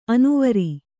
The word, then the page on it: ಅನುವರ: anuvara